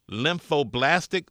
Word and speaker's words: lymphoblastic: Marley has acute lymphoblastic leukemia.